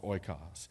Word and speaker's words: oikos: It's pronounced oikos.